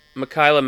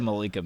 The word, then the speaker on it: Mikaela: Mikaela Mayer? – Yeah. – What? Who did you say the first time? – I said Malika Mayer.